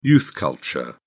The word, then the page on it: youth culture: British English